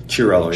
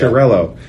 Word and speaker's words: Chiarello: And you have one player, I never know how to pronounce his last name. Is it Chiarello? – Chiarello, yeah. – Chiarello.